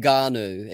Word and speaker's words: Ngannou: Ngannou. It's N-G. Yeah. Okay. Ngannou.